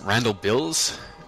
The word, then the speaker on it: Randall Bills: if I pronounce his name wrong, you guys will be grumpy, but is it Randall Bills? We consult with him quite readily. – Yes, home run there.